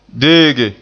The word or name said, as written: deege